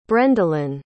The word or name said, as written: brendolyn